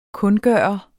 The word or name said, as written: kundgøre